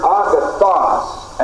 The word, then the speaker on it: agathos: Two of the chief Greek words which are translated good are the words agathos and kalos. Agathos is spelled A-G-A-T-H-O-S. And here's the Greek equivalent of it, A-G-A-T-H-O-S. The other word is kalos, K-A-L-O-S.